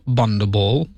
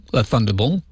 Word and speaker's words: Thunderball: He nearly passed out after holding the final high note for the James Bond theme song from Bundaball, Thunderball, or Bundaball, if you can't pronounce it properly.